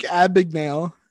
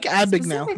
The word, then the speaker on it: Abagnale: How do you say his name? Abagnale? – Frank Abagnale. – Abagnale. There's no R? – I don't know.